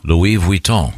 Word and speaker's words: Louis Vuitton: Louis Vuitton.